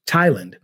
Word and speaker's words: Thailand: It is written TH, but we don't say Thomas. We say Thomas with a T, ta-ta, Thomas. Or Thailand, the country, also Thailand.